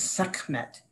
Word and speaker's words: Sekhmet: I'm not sure how to pronounce S-E-K-H-M-E-T. – Sekhmet. Yes, Sekhmet. And you can make it even short, Sekhmet, right?